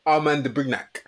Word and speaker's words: Armand de Brignac: Armand de Brignac.